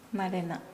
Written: malena

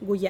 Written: guya'

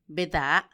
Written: bedaa

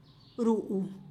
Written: ruu

